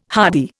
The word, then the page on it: hardy: Indian